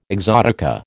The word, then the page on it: exotica: American English